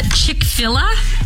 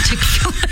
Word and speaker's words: Chick-fil-A: They ordered uh, Chick-fil-A. Chick-fil-A? Oh, Chick-fil-A. I knew I was saying it wrong as soon as it came yeah, out.